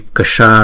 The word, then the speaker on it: ksha: Nhưng chữ s này nè, chữ ksha này nè, là chữ s này chúng ta cong lưỡi lại và búng ra. Ksha ksha ksha. Nhưng chữ k, chữ s, chữ a nè, đọc là ksha.